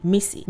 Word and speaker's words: Mici: My name is Bliss Akinyemi, but I prefer that you call me Missy. Missy is spelled M-I-C-I and pronounced M-I-S-S-Y. That's right. You need a pen. Grab one. M-I-S-S-Y. That's how you pronounce Missy.